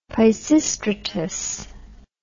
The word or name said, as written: Peisistratus